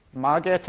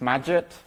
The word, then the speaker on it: Magit: If anyone's familiar with Margit, or I think it's pronounced Magit, for Emacs, this is a direct clone that works surprisingly well in VS Code.